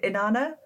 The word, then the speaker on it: Inanna: I'm going to pronounce this horribly, but is it Inanna? – Yeah, she's the oldest and best recorded of them.